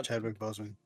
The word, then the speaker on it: Chadwick Boseman: Yeah, Chadwick Boseman.